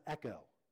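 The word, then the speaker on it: echo (ἔχω): It's pronounced echo. That's why it's my favorite.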